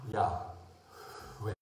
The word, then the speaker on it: Yahweh: How do you pronounce it? You say, breathe in. – Yahweh. And that is the H, the hey of God.